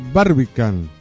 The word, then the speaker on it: Barbican: No es el caso del teatro de Barbican, ¿así se pronuncia? – Sí.